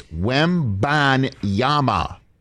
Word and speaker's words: Wembanyama: It's Wembanyama.